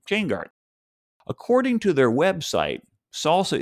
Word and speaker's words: SLSA: There's a new industry collaboration called the Supply Chain Levels for Software Artifacts, or SLSA, pronounced SALSA, whose URL is slsa.dev.